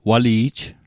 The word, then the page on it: wali'j: Mi'gmaq